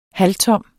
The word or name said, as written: halvtom